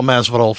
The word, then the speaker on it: Masvidal: We got a great interview coming up on this episode with Paul Masvidal. There we go. I got to say it right. Paul Masvidal from Cynic and Death to All and so many other things.